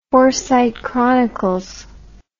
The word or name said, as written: Forsyte Chronicles